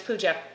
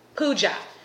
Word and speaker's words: Puja: Hi, my name is Pooja. What? Pooja. What? Pooja. Spell it. P-U-J-A.